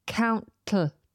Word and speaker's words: countless: I keep the tongue firmly up there and then the T kind of explodes out of the side of my tongue. Countless, countless. It's a little bit tricky, but that gives you an idea of how we pronounce it.